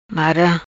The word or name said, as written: Mara